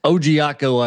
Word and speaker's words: Ojiako: and John Ojiako from I'm probably butchering that, from Virginia Tech. – Ojiako, I believe. Yeah, not too bad.